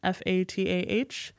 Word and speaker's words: Fatah: Um, specifically, there was like the Palestinian Liberation Organization, the PLO, um, and FET, F A T A H. Um, I think in like American pronunciation, it's Fatah.